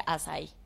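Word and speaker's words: açaí: Se escribe acaí y se pronuncia azaí para que sí la encuentren.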